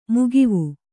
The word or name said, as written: ಮಗವು